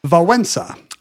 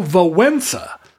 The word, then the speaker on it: Wałęsa: What does he say? – Wałęsa. – Oh, Wałęsa. – So the S is kind of nasal.